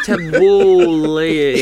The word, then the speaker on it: Tabbouleh: How do you say that? Tabbouleh. Yeah, that's correct. Yeah, that's right.